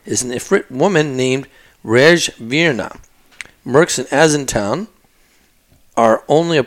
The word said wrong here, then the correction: Asana: portion of, or maybe it's Asana Town. I think it's Asana Town. Asana Town. – How do you spell it? – A-S-A-N-A Town.